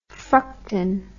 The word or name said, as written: fructan